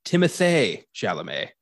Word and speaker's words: Timothée Chalamet: We apparently been mispronouncing Timothée Chalamet's name. The apparent correct pronunciation of it is Timothée Chalamet. I'm not kidding. I read an article about like famous actors whose names are often mispronounced, and that was one of them. – I'm so sorry, Timothée Chalamet. – Timothée. His name rhymes.